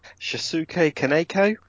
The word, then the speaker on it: Shinsuke Kaneko: This is an adaptation of the wildly popular anime and manga, directed by Shinsuke Kaneko. I believe I'm pronouncing that right. Am I wrong? Assume, tell me. – Shinsuke Kaneko.